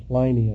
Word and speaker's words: Pliny: I don't know if I'm pronouncing this guy's name right, but it's P-L-I-N-Y, Pliny, I guess, the younger, Pliny, the younger.